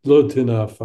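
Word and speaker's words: lotinaf (לא תנאף): altinaf, lotinaf, I'm sorry, but is not English.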